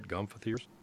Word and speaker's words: gomphotheres: Did I say that right, gomphotheres? – Yes, yes.